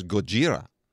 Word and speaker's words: Gojira: name in Japan is Gojira.